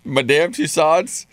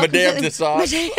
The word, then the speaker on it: Madame Tussauds: Madame Tussauds? Madame Tussauds. – Oh, how do I say it all? – Madame Tussauds.